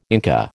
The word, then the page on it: Inca: American English